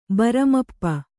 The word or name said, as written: ಬರಮಪ್ಪ